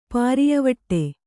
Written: ಪಾರಿಯವಟ್ಟೆ

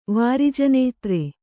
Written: ವಾರಿಜನೇತ್ರೆ

